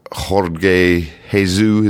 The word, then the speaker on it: Jorge Jesus: Jorge Jesus, is that how we pronounce it? Or absolutely. – George Jesus? – Yep.